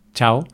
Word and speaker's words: ciao: Como se escribe en italiano, en italiano la pronunciarán chao, pero nosotros, claro, nuestra pronunciación sería con ch, entonces chao.